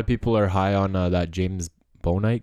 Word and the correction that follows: Bouknight: guy. Is that how you say it? Bouknight.